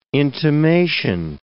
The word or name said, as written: intimation